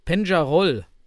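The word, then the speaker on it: Peñarol: noch den Hinweis von Sergio online, der uns gesagt hat, dass in der Südamerikameisterschaft hatten wir ja da von Penjarol geredet, die so werden die wohl richtig ausgesprochen.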